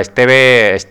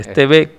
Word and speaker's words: Steve: Esteve Kutz. – Pero Steve, ¿quieres decir? – Steve, sí. Vale. Steve Kutz. Yo es que no sé inglés, ya sabes. Sí, como... Steve Kutz.